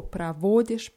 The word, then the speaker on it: проводишь: Ja, und wichtig ist bei diesen ganzen Wörtern die Betonung auf dem O, pravodisch, pravodit.